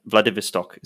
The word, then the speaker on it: Vladivostok: Like, for example, you know, the Vladivostok, is that how you say it? – Yes.